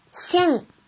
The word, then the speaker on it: 千: 一千的千，音读的时候读作 sen sen sen，比如一千日元 sen yen sen yen sen yen。